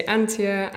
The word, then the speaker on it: Antje: So that's an interesting question, because the German way to pronounce it is Antje. But I tell people to say Antje, that's fine.